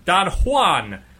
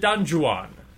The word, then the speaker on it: Don Juan: DJs, dirty joke, desk jobs, Don Juan, or is it Don Juan? – I think it's, the J is silent.